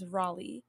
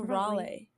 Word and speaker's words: Raleigh: I've recently been informed that the way you say this town's name is Raleigh. And I've been saying Raleigh for the longest time. I was pronouncing the name of this town wrong. It's Raleigh.